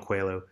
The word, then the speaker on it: Coelho: Coelho, yeah. – There we go, Paolo Coelho.